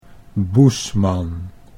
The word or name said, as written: Boesman